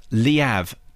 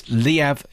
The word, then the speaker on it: Liev: Kurt says it is pronounced Liev to rhyme with he have. – Really? – It's Liev Schreiber.